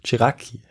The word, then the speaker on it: Ceracchi: ist der italienische Künstler Giuseppe Ceracchi, ein äh, Jakobiner. Ja, habe ich hier richtig ausgesprochen. Das klingt gut, ja. – Oder? – Chiraki, ja, genau.